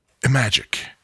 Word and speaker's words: Imagic: Because he's always like, is it Imagic? Is it Emagic? Pretend it's the word imagine, but instead of the N sound, say K. So, Imagic. – There you go. That's actually a good idea. – Imagic.